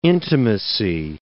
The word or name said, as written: intimacy